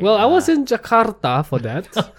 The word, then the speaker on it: Jakarta: I just got back from Jakarta. – Ooh. – Notice right. The rolling of the R. – Yes, yeah. That's how they say it. – Do they? – Jakarta.